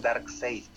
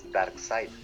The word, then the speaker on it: Darkseid: Hay algunos que le dicen Darkseid. – Ajá. – Pero es Darkseid. – Darkseid. – La E se pronuncia como A.